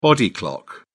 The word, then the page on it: body clock: British English